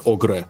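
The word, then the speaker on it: Ogre: Город называется Огре, ну, типа, как сам пишется.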